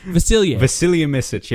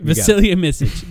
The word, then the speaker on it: Vasilije Micic: Did I say that right? We're trying to figure out exactly. Vasilije Micic. – Yep. – Vasilije Micic. There we go.